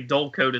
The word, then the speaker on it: Dullcote: It'll say twelve sixty, and it'll say Dullcote. It's spelled D U L L C O T E. So yeah. it's like cote, dull cote.